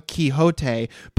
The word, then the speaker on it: Quixote: Well, here's the thing is that I think this is a great discussion to have because it is pronounced Don Quixote, but when weird Anglophone scholars use the word quixotic, they say quixotic, which to me might just be disrespectful of Spanish.